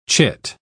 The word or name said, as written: chit